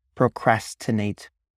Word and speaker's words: procrastinate: The pronunciation pro crass, tin, nate, the number eight, right? Pro, professional, crass, like brass or classroom, tin, tin man, nate, n, eight, number eight, procrastinate, right? Tin eight, right?